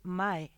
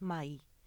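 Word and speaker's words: mãe: Si tú escribes pachamae, la gente dice mae. Uh-huh. No, es mai.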